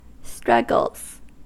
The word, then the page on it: struggles: American English